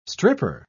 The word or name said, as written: stripper